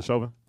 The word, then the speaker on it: Chauvin: Is it Chauvin? – Yeah.